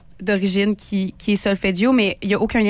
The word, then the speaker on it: solfeggio: La fréquence sacrée, là, tu m'as parlé de la fréquence solfeggio. Est-ce que je le prononce bien? – Solfeggio, c'est parfait.